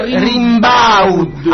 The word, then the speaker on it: Rimbaud: E come si pronuncia? – Fascisti. – Allora si pronuncia Rimbaud. Arthur Rimbaud.